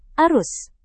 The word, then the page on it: arus: indonesian